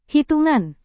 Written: hitungan

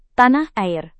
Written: tanah air

